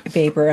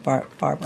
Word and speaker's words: Baber: It's Baber? – It's Baber, yeah. – Baber, and I said Barber.